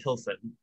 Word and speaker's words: Pilsen: Pilsen is how you pronounce it. It's west of Prague, so it's like, yeah.